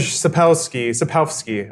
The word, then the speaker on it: Sapkowski: Andrzej Sapkowski. I did the phonetic pronunciation on Wikipedia, and apparently the W is an F sound.